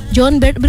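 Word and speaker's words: Jon Bernthal: John Bernthal, benar enggak aku pronounce-nya kakak?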